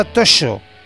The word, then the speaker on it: Toxo: el nombre de Toxo se pronuncia Toxo.